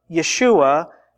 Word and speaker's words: Yeshua: There have been discovered the tombs of more than 70 who were known by the name of Yeshua, that is the Hebrew pronunciation of this name, Jesus.